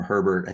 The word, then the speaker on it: Herbert: I've always called him Herbert. I think you pronounced it the correct way, Billy.